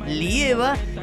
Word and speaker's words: Lieva: YouTube, Lieva, lo vas a encontrar como Lieva con acento en la E, Lieva, y también lo vas a encontrar en Spotify.